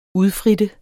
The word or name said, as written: UDFRITTENDE